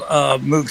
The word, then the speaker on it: Moog: And so I borrowed a really old Moog synthesizer from a friend of mine. It's before the Mini Moog came out. And oh, nice. – I know it's pronounced Moog, but I grew up saying Moog.